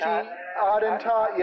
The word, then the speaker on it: Attentat: edition of Attendant, or whatever it's called, A-T-T, Attentat, yeah, that's what it is, Attentat, You see, that's what I'm talking about right there.